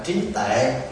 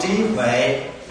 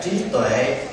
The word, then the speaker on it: trí tuệ: Miễn sao đừng có phát âm là trí tệ. Còn trí huệ hay là trí tuệ đều được hết.